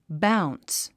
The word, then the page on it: bounce: báuns; バウンス